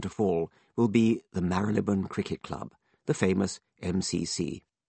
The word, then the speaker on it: Marylebone: The pre-mutant pronunciation of one particular station is Marylebone. The mutant pronunciation on the Bakerloo line, spoken by a young woman's recorded voice, is Marylebone.